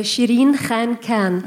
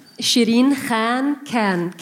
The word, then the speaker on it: Sherin Khankan: It's uh, Sherin Khankan. Oh, damn. Khan. say it again. Sherin Khankan. KH, it gives the mm. sound of Kha.